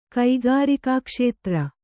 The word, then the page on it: ಕೈಗಾರಿಕಾಕ್ಷೇತ್ರ: kaigārikā kṣētra